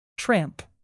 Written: tramp

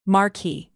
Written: marquee

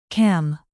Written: cam

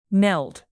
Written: meld

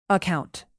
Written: account

